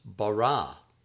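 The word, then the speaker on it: bara (בָּרָא): Word 25, create, is pronounced bara, bara.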